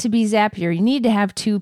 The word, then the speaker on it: Zapier: i know it's supposed to be pronounced zapier but what are your feelings on this did they just misspell it i think they, they with...